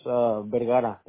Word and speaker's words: Vergara: My name is Jose, and my last name is Vergara. That's Diaz Victor, E-R-G-A-R-A. – Okay, V-R-G-A-R-A? – Yeah, B-E-R-G-A-R-A, Vergara.